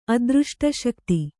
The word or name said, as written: ಅದೃಷ್ಟಶಕ್ತಿ